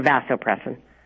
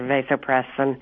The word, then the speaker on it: vasopressin: Am I pronouncing that right? Or vasopressin? – You know what? You can pronounce it either way.